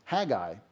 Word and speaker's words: Haggai: It's Haggai, but I will mispronounce it.